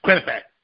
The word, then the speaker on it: Quinnipiac: Quinnipiac. – That's from everybody. Quinnipiac. Can't spell it, but it's Quinnipiac. Okay, so that's saying he's at 33%.